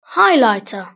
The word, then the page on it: highlighter: British English